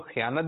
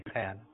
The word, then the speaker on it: خیانت: تلفظ بتا دیں, کچھ لوگ خیانت بھی کہتے ہیں, خیانت بھی. – مجھے تو یہ یاد ہے خیانت. – خیانت چلیں جی, خیانت.